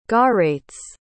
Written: garaitz

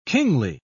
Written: kingly

